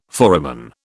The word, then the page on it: foramen: British English